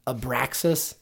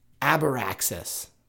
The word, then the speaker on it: Abraxas: Have you ever heard of the old feller Abraxas? – Maybe I have heard of Abraxas. – Or when it's sometimes pronounced Abraxas, which sounds a little bit more like the Abracadabra part.